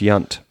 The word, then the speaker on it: Yount: It's pronounced Yunt. no matter what. And no matter what letters are used, it's always Yunt.